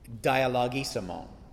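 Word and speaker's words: dialogisimon (διαλογισμῶν): And the interesting thing about complaining, and the word that Paul chooses to use is the word dialogisimon. I'll say that again. Listen to the beginning of it again. Dialogisimon. It sounds like the word dialogue, and it is a dialogue, but it's a dialogue with yourself.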